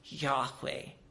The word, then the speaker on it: Yahweh: In Hebrew, it is the English letters are YHWH, possibly pronounced Yahweh, which is actually like breathing. Yahweh.